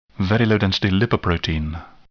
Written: very low density lipoprotein

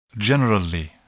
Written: generally

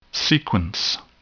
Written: sequence

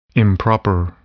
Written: improper

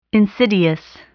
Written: insidious

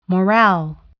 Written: morale

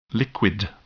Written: liquid

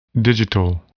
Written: digital

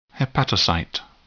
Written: hepatocyte

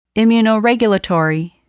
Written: immunoregulatory

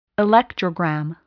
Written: electrogram

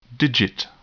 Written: digit

